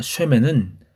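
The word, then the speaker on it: 쉐멘 (שֶׁמֶן): 이름의 히브리어 발음은 쉼입니다. 기름의 히브리어 발음은 쉐멘입니다. 쉼과 쉐멘은 모음과 자음 하나씩만 추가된 것인데요.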